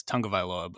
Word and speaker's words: Tagovailoa: So but I would say, yeah, Burrow and Tagovailoa, easily the top two guys. And I say his name all the time wrong. It's Tungavailoa, but um, trying to break that habit.